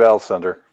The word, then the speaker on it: Alcindor: How do you say that? Alcindor? – Yeah, Lou Alcindor.